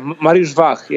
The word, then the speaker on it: Mariusz Wach: Yeah, Mariusz Wach. Yeah. – Mariusz Wach.